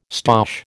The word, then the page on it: stoush: American English